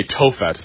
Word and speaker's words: Tophet: A Tophet, that's spelled T-O-P-H-E-T, a Tophet.